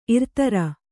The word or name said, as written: ಇರ್ತರ